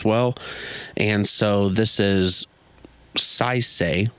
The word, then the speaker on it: Saisei: I'm going to butcher this name. Saisei. It's spelled S-A-I-S-E-I.